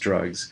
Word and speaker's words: DRXUGS: It has the acronym DRXUGS, pronounced drugs.